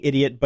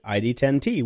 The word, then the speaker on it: ID10T: Everybody keeps saying idiot, but he pronounces it ID10T, which is an old tech code for people who are idiots.